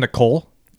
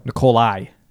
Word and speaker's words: Nikola: Did you just call him Nicole? – Nikola Tesla. – Nikolai. – It's Nikola.